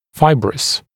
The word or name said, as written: fibrous